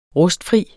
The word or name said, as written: rustfri